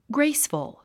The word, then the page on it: graceful: ɡréisfəl; グレイスフゥ